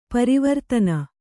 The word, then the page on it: ಪರವರ್ತನ: Kannada